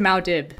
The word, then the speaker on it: Muad'Dib: But I always thought it was Mao Dib. – Oh, that makes sense. Especially since Mao Dib is supposed to be for mouse, basically.